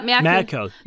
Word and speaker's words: Merkel: Merkel. Or is that how you pronounce it? Merkel. – Yeah, Merkel.